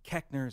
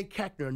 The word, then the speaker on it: Koechner: But they say Koechner. Nowhere in the English language does the O-E make the short E sound. – Right. – But my family said Koechner.